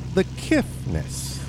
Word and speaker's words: The Kiffness: As we take a look at the Kiffness. – Also known as David Scott. – Yes, and there's no T in that, right? – There is no T in that. – So I'll say it right.